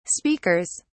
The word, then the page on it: speakers: British English